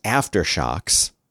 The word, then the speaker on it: Aftershokz: The name of the company is Aftershocks, And it's spelled AFTERSHOKZ.